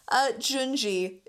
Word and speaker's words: Junji: Junji is how you say the first name.